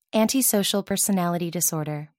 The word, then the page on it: antisocial personality disorder: American English